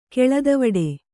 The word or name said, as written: ಕೆಳದವಡೆ